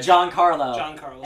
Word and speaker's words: Giancarlo: Even though Juan Carlos Esposito, did I say it right? – Giancarlo Esposito. Wow. Mm-hmm. It's spelled Giancarlo.